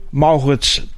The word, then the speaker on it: Maurits: Naast mij zit Maurits dochter Om. Zeg ik dat goed, Maurits? – Dat zegt u helemaal goed.